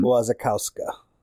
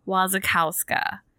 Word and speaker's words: Wasikowska: Wasikowska. – Wasikowska.